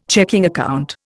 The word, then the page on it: checking account: Indian